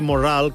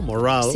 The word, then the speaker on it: Morrall: I de resulta que sa darrera resposta era Earl Morrall, que no sé ni siquiera si l'hauré pronunciat bé jo. – Earl, és el nom, i Morrall, que era el que preguntàvem. Morrall, no? Morrall.